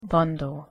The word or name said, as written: bundle